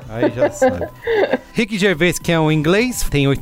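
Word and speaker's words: Ricky Gervais: Rick Gervais.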